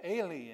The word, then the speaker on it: Eli: In Aramaic, it's Eli, it's my God.